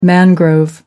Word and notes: mangrove: American English

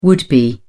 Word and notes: would be: British English

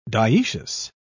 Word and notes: dioecious: British English

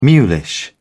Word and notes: mulish: British English

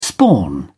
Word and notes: spawn: British English

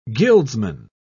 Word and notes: guildsman: British English

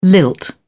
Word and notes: lilt: British English

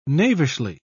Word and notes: knavishly: British English

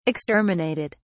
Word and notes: exterminated: British English

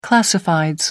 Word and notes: classifieds: American English

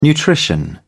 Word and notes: nutrition: British English

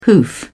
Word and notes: hoof: British English

